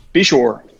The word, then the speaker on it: Beshore: Bishore.